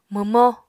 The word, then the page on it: mwmó: Apatani